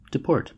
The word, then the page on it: deport: American English